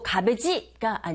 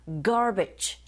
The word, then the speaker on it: garbage: Process, 그리고 garbage가 아니라 garbage. 첫 번째 강세 꽝 주시고 in D로 발음하는 거 아시죠?